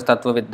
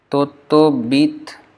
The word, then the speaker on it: Tattvavit: So in Bengali this would become Tottobit Das, and the Das sometimes they pronounce as Dash. So Tottobit Das. So my name will become like this.